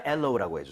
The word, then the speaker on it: aloe: 하지만 엘로우라고 해주세요.